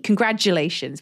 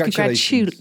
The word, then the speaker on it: congratulations: But I always say congratulations, like a D, congratulations. But it's congratulations.